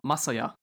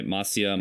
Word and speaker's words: Masaya: But as you know from the Parappa episode, Parappa was made by Matsuya Matsura. I believe Masaya. I can't pronounce his first name. – Oh, Masaya. – So it was made by Masaya Matsura.